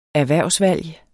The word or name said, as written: erhvervsvalg